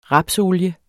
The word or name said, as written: rapsolie